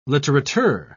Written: litterateur

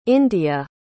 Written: indiya

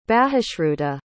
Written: bahushruta

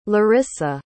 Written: larysa

